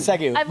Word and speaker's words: segue: I've learned segu. Richard told me it's pronounced segu, not segue.